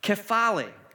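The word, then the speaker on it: kephale (κεφαλή): It's a word called kephale. It's spelled K-E-P-H-A-L-E.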